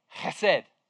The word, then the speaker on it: chesed: This is actually loving kindness, or chesed. If you remember, when I've preached before, I love the Hebrew word chesed in the Old Testament. Come on, say it with me, chesed.